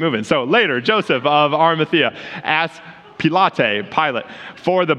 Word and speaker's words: Arimathea: Let's just say I said the name right, Arimathea.